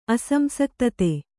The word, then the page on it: ಅಸಂಸಕ್ತತೆ: Kannada